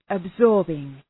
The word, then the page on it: absorbing: əb’zɔ:rbıŋ, əb’sɔ:rbıŋ